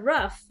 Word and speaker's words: rough: But there are other words like dough, which has O-U-G-H, and it's not U, it's O, dough. Okay. And then there's the word rough, which is R-O-U-G-H, and it's not ooh or oh, it's uff.